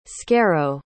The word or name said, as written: scarrow